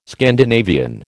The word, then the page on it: Scandinavian: American English